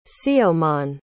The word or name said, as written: siomon